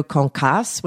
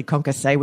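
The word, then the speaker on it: concassé: You've been saying tomato concasse when it's really concasse, which I think right.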